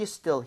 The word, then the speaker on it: still: 자, 그리고 전에도 말씀드렸지만 얘는 그 훔치다 할 때 S-T-E-A-L과 스펠링도 틀리지만 발음 자체가 그렇죠. So steal 이렇게 E 이러면 안 돼요. 얘는 입모양 벌리지 않은 상태로 편하게 갈게요. 두 번 갑니다.